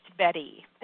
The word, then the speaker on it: Bette: By the way, I know it's a weird it's weird the way I spell my name, but it is pronounced Betty.